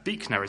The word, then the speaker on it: Buechner: We'll look down at that quote from Frederick Buechner, is apparently how you pronounce his surname.